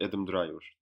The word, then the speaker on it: Adam Driver: Bir kere Ha e, Driver evet Adam Driver doğru.